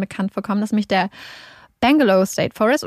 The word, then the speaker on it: Belanglo State Forest: oder ich glaube, ich meine sogar, dass ich in der zweiten Folge das mhm. dann auch immer genauso ausgesprochen habe, wie man es geschrieben hat, was mich Belanglo State Forest ist. Aber wie auch immer, wo auch immer die Extrasilben herkommen, die Australier nennen es Bangalore State Forest.